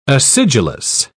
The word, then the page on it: acidulous: British English